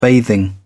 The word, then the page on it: bathing: British English